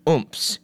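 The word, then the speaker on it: AUMF: However, Congress has often passed authorizations to use military force, or AUMFs, which are onomatopoeically pronounced oomps.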